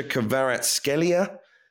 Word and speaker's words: Kvaratskhelia: I hope I pronounced this correctly. Kvaratskhelia Kvaratskhelia, known as Kvaradonna to the Napoli fans, who's been very, very impressive so far. How good is he? – Oh, he's wonderful. I mean, he's, and congratulations. Well, I mean, I'm not Georgian and I don't speak Georgian, but I think that was a really good pronunciation, and it also saved me having to do it.